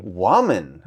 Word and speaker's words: Wuaman: Am I saying that right? – Wuaman. – Yeah. All right. Wuaman. See? That's why we always ask how it's pronounced, because I definitely put the American phonetic on there.